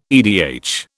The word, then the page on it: edh: British English